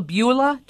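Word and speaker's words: Abuelita: It's called a Beulah. Do I say that right? It's Spanish for grandma. – Yes.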